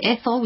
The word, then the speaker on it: Ethel: So it's Ethel, which is E-T-H-E-L, and Hammond, it's spelled H-A-M-M-A-N.